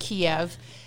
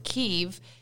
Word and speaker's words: Kyiv: So growing up, we've called it Kiev. And that is the Russian pronunciation, as I understand it, of what the city is. Now, people who have lived in Ukraine have always called it Kyiv.